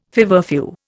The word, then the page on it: feverfew: Indian